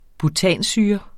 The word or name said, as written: butansyre